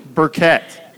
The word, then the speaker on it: Burkett: Burkett? – Burkett. – Burkett. Kind of like a charcoal Burkett, right? – Yep. – Is that what that's called? – Yeah. It's spelled differently, okay. But yes, the same.